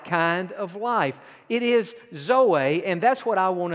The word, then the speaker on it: zoe: And then the third word that is the most important of all the Greek words for life is the word zoe. And you spell it Z-O-E, Zoe, and that word simply means spiritual life.